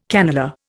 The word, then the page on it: cannelure: Indian